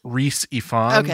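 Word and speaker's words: Rhys Ifans: Reese Ifans.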